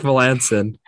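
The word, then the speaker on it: Melancon: How do you pronounce this last name? – Mark Melanson.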